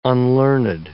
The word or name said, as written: unlearned